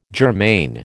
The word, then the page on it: germane: American English